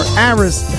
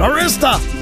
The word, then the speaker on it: Arista: And John Miles, UK musician, never really had a huge hit here, but started on London Records, then went to Arista Records, or Arista, however they pronounced it, Arista!